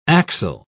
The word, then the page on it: axile: British English